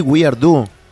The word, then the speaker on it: weirdo: Weirdo. Ok, se pronuncia así, weirdo.